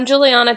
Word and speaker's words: Juliana: I'm Juliana Chauncey. A lot of people call me Chaunce. Juliana's four syllables, which people oftentimes decide is too long.